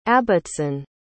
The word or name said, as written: Abbotson